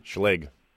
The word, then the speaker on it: Schlage: How do you pronounce it? – Schlage. – That's what I said. Schlage. – All right. – How did I say it? – You said it a little weird.